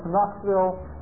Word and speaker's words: Knoxville: For Rabbeinu's example is the K in Knoxville. Nobody pronounces it for Knox. For Knoxville, the K is totally silent in pronunciation.